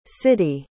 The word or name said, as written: siddie